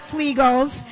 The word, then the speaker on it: Flegal's: Oh, we are live at Flegal's. Rhymes with Legals.